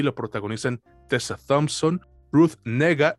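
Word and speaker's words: Negga: Es que, es que ella ha dicho, que se pronuncia Nega o, Nega, no sé, pero es así, es Ruth Nega, Andrew Holland, Alexander Skarsgård y Bill Camp.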